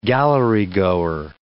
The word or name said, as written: gallerygoer